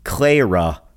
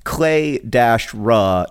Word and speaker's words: Clara: I don't have a very good grammar, but I do have this question from Clara, not Clara. She wrote that her name is pronounced like Clay-ra, not Cla-ra, but I actually don't find that helpful.